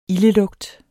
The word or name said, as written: ildelugt